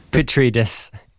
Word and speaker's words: Petridis: I shouldn't mispronounce his surname. – Petridis. – Yeah.